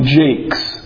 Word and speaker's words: Jacques: Well, there's a famous author, and his name is Brian Jakes. And that's how he pronounced it. And then I worked with a guy at the pawn shop who had the same exact spelling, last name, and he pronounces it Jacques, which is how I always thought it was pronounced.